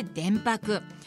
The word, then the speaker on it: 伝泊: 三菱電機プレゼンツ戸田恵子大人クオリティ今日フォーカスするのは電白です伝えるに止まると書いて電白、